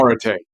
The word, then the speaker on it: arete: You know, I think we're talking about arete. Am I pronouncing it correctly? – Arete. Yeah, arete. Arete, which, you know, is a way of living, which I think you're a proponent of.